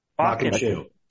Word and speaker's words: Bakken: Bakken.